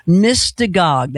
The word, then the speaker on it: Mystagogue: Mystagog.